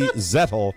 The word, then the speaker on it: Zettel: That's from Stacy Zettel, rhymes with kettle.